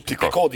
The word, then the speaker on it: Picot: Picot diyorlar ama Fransızca Picot diye.